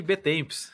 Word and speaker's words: Betemps: Henrique Betemps.